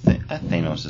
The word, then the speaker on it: Thanos: What's the correct pronunciation? – Thane? – No. Thanos? Thanos? – Thanos is fine.